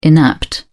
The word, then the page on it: inapt: British English